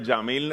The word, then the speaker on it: Yamil: Mi nombre es Yamil Alejandro, se escribe con Y